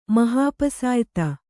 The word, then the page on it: ಮಹಾಪಸಾಯಿತ: mahāpasāyita